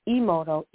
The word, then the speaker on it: Emoto: and I pronounced his last name Kimoto, which is Emoto, E-M-O-T-O, and this doctor Masaru Emoto, and he did the experiment about the water.